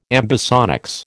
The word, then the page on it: ambisonics: American English